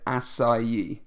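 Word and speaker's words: açaí: Yes, folks, that's how you pronounce it acai.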